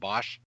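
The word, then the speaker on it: Boesch: How do you pronounce his last name? Is it Bosch? – Yes.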